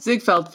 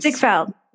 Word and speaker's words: Ziegfeld: Like they're like the Zieg- Siegfried. Is am I saying that right? Ziegfeld Follies. Ziegfeld dancers.